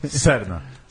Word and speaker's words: Serna: Mas e aí, Fagner ou Sarna? – Serna. – Serna. Você estava respondendo ou estava corrigindo a pronúncia? – Não, estava respondendo. Quem sou eu para corrigir sua pronúncia? Que eu ouvi Serna. Mas... – Podiam meter uma escapão vogal Sarna? Ali, né? S-R-N-A, né?